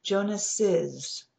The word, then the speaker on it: Jonas's: Pronunciation, Jonas, his name, Jonas's. So when you speak the apostrophe S, you'll add an extra syllable. Jonas, Jonas's. Jonas's father's title was nurturer, and nurturer also capitalized.